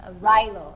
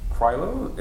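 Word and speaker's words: Clyro: Yeah, Rilo. It's like C-R-Y-L-O. Biffy. – Oh, okay. Biffy Krylo? – No. Like, yeah, but we don't pronounce Cedar.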